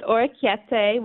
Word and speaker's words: orecchiette: It's orecchiette with buttermilk, peas, and pistachios.